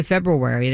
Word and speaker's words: February: What about, do you say February or February? – See, people say February, and it's February. – See, I say February. – It's not February.